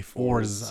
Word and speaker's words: Forze: F-O-R-Z-E. – Okay. Fours. – Fours is Break. B-R-A-E-K.